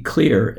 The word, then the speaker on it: Xlear: Malcolm and I hosted Mr. Nate Jones, and Nate is the CEO of the company Clear. It's spelled X-L-E-A-R.